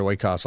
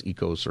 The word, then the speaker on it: oikos: Oikos, I think, is the official academic pronunciation, but if you're speaking modern Greek, I think it's ecos or something.